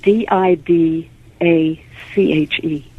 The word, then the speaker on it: Didache: It's called the Didache, and that's uh, that's spelled D I D A C H E.